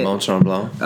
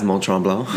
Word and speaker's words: Mont Tremblant: How is Mont Tremblant? Is that how you say it? – Mont Tremblant. – Yeah. You can say it with a bit more of a pizzazz, Mont Tremblant.